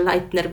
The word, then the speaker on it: Leitner: Tą osobą jest Małgorzata Leitner, chyba tak należy to przeczytać, bo pisze się przez EI, więc tyle co się w życiu uczyłam niemieckiego, no to z tego wnoszę, że Leitner będzie właściwą wymową.